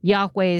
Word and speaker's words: Yahweh: or Yahweh is how we say it sometimes, pronounce it, which gets somewhat removed.